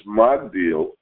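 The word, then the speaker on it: Magdil (מגדיל): Nevertheless, in our verse, it is pronounced Magdil, while in Samuel, it is changed altogether and pronounced Migdol.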